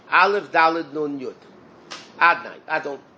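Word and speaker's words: Adonai: Hashem's name, even though it's written Yud-Kei Vav-Kei, and there's certain vowels on the letters of Yud-Kei Vav-Kei, but we pronounce it Adnai.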